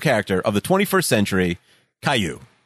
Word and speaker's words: Caillou: Caillou. – No, it's definitely Caillou. The Wikipedia starts with pronounced Caillou.